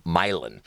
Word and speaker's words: Milan: Well, funny enough, that band, and it's spelled Milan, but it's actually a Czech name, and it's Milan. It's Milan and the Sour Goat.